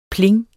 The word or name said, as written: pling